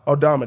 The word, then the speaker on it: Aldama: How do you say his name? Aldama? – Santi Aldama? – Aldama. There you go, actually.